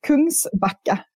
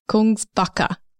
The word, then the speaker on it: Kungsbacka: So it's the Kungbacker kitchen doors. Is that how you say it, first and foremost? – Close. It is Kungsbacka. – Kungsbacka.